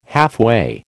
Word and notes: halfway: American English

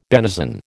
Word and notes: benison: American English

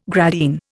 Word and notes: gradine: Indian

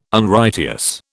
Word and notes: Unrighteous: British English

